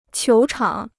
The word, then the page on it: 球场: qiú chǎng